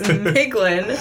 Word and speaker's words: Miglin: Milgen. – No, I misspelled it there. Oh. It's Miglin.